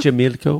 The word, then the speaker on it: Xochimilco: Xochimilco.